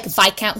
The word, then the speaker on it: Viscount: Because, you know, like, Viscount Who Loved Me is the book, so it's, like, by Julia Quinn, but it's Viscount, so I've also been saying it wrong my whole life, but you learn something new when you watch TV.